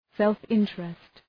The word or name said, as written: self-interest